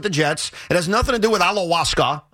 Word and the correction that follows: ayahuasca: How do you say it again? – Ayahuasca.